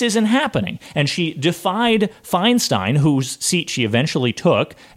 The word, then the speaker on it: Feinstein: I never know how to pronounce it. – It's Feinstein. It's Feinstein. – Thank you. Feinstein. I always pronounce it wrong.